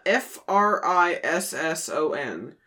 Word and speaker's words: frisson: No, it's frish. Yeah, it's pronounced frisson, but it's F R I S S O N.